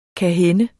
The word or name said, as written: kan